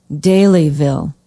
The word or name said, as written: Daleyville